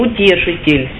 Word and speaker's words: Утешитель: Это Утешитель. Утешитель. Но я по-славянски говорю, ударение делаю на второй слог. По-русски, наверное, утешитель. Ну, буду говорить по-славянски. Привык уже.